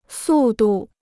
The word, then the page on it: 速度: sù dù